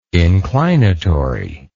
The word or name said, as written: inclinatory